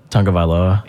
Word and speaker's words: Tagovailoa: Tagovailoa? – Yeah.